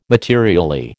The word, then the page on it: materially: American English